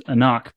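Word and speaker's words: AnCnoc: It's pronounced Anock, but spelled weirdly.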